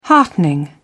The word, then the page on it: heartening: British English